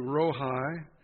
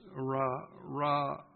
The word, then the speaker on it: Rohi: Jehovah, I'm going to say Rohi, R-O-H-I. The name Jehovah Rohi, it might be pronounced Ra-ah.